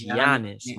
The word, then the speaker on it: Giannis: Don't call that man Giannis no more. That man name is Giannis. Giannis. – My God. – You don't pronounce it like that. It's Giannis, my brother.